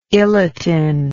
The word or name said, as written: iletin